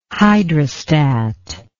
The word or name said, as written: hydrostat